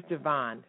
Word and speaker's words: Javon: It is Javon, right? – Yes, Javon.